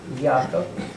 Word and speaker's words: Яков: Яков. Ударение на Е.